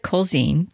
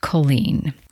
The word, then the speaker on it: Culzean: Now, it looks like Culzean, Culzean, but it is actually, as Bo informed me, because she always corrects my pronunciation, which I actually appreciate, it is pronounced Culzean, Culzean Castle.